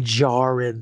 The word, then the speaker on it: Djarin: It's Din Djarin. The, the D is silent.